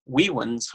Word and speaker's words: vivens: Or for the Latin, classical Latins out there, vivens homo, vita autem hominis visio Dei. Classical Latin, you pronounce the V's as W's.